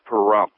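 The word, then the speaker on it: Pahrump: Yeah, it's pronounced perump. Sounds like there should be a rim shot right after it, right? – Yeah, you saw my hesitation with it, right?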